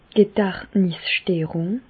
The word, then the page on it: d' Gedächtnissterùng: Bas-Rhin; Reichshoffen